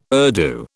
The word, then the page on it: Urdu: British English